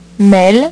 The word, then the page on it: mele: French